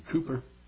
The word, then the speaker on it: Cowper: Old Cowper, pronounced him Cooper, William Cooper in England.